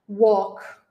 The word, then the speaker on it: walk: Walk. A gente pronuncia assim, não tem o som do L e não é o som de A, né? É um som de O.